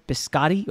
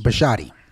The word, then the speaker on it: Bisciotti: So we're Baltimore and Bisciotti. Did I say that right? Biscotti or Bisciotti? – Bisciotti. – Bisciotti, yeah.